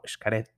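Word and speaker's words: Xcaret: Y, eh, por ejemplo, este tema de que la X se pronuncia como no, Shkaret Tishkoko, eh, Yashilán, ¿no?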